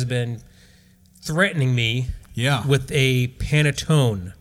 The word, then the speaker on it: panettone: But it's so softly pronounced by panettone. An actual Italian. It's panettone.